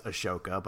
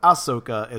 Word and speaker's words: Ahsoka: And I think most importantly for us, the inspiration for the name Ahsoka, which I keep mispronouncing as Ashoka, but Ahsoka is, of course, a very popular Jedi character.